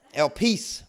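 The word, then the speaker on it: El peace (ἐλπίς): Even when you do a word study and you look at the word hope in the New Testament, it's the Greek word elpis. And I had trouble pronouncing that word. I had to look it up. El peace, right?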